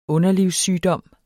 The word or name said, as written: underlivssygdom